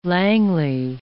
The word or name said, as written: Langley